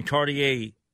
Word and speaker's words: Cartier: Some of the items I'm looking at here on page six, let's see, we have a Cartier, is that how you pronounce it? – Cartier, yeah.